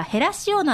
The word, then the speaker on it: 減塩: はい、ということでこの大浦へら塩鍋減塩と書いてねへら塩と読むということなんですけれども、こういった鍋が誕生するきっかけになったということなんですが、先ほどの話でその大浦女性の会という、ね、会が出てきたんですが、南館長これはどういった会なんでしょうか。